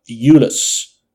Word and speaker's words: Uhls: This is a film written for the screen by a unknown writer called Jim Uhls. Um, it's actually spelled U H L S, but when I looked it up, that's how you pronounce it.